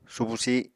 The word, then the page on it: subu sih: Apatani